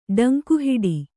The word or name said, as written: ಡಂಗಹೊಡೆ